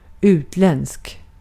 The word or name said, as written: yabancı